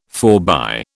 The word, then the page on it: forbye: British English